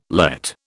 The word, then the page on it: Lett: British English